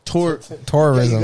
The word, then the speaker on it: tourism: So they've been focusing on terrorism, like all these other things. – Terrorism? – Tourism, yeah, but not terrorism. – You really gotta pronounce it better.